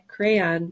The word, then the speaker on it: crayon: Crayon. Crayon. I think I say crayon. – Crayon. – All right, yeah, I don't pronounce the Y.